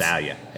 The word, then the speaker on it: Australia: It's, Australia.